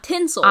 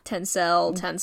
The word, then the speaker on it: Tencel: and tencel. I think that's how you say it. – Not tinsel. – I'm sorry. Tencel. I am so sorry if I'm saying that wrong. – Tencel.